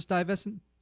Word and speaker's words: Stuyvesant: So this movie takes place on Stuyvesant Avenue and Lexington Avenue, which do y'all pronounce it Stuyvesant? – Stuyvesant.